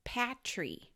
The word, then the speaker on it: Petrie: Petrie is how I would have pronounced it, but they say it Patrie.